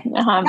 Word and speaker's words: Viura: I don't know how to say this other word, V-I-U-R-A, Viura? – V-I-U-R-A? – Yeah, the I with the acento. I don't know how to say that. – Viura. – Viura, Viura? Okay, Viura.